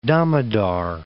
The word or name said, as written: Damodar